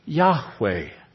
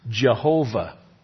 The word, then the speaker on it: Yahweh: It was a very, very ancient language, but probably best pronounced Yahweh. Because of the influence of German on the English language today, perhaps you've heard it pronounced Jehovah. That's an improper pronunciation, by the way, because in the Hebrew, there is no J and there is no V.